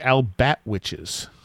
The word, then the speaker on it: Albatwitch: You have a particular type of Bigfoot in that area called the Albat Witches. – Yes, yeah. Albatwitch is the how I've been pronouncing it. I've heard Albatwitch. I've heard any number of variations.